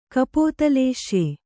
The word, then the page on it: ಕಪೋತಲೇಶ್ಯೆ: kapōtalēśya